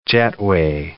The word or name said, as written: Jetway